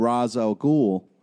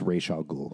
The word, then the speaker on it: Ra's al Ghul: Like, because the only time I've seen him climbing a mountain is for, like, when he's trying to find Ra's al Ghul. So I'm just wondering if I missed something. – It's pronounced Ra's al Ghul.